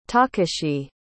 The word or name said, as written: takashi